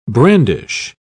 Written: brandish

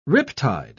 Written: riptide

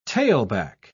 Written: tailback

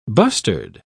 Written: bustard

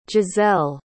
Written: giselle

